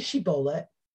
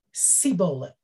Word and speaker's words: shibboleth: They said, say Shibboleth. And they said, Sibboleth. They could not pronounce the shin.